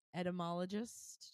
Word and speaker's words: entomologist: Well, that's what they're so there's home, like this entomologist. Did I say that right? – You did.